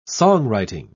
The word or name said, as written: songwriting